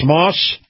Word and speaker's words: Maus: So that's Moss Family Automotive. Moss is spelled M-A-U-S.